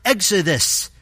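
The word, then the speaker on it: exodus (ἔξοδος): The Greek word for departure here is pronounced exodus, and is speaking of his coming death.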